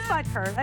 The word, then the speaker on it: Butker: It is Butker. I said it right.